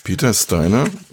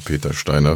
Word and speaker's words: Peter Steiner: Peter Steiner, so wird er jetzt natürlich auf amerikanisch-englisch ausgesprochen, also Peter Steiner für Leute, die nicht wissen, wie Englisch geht.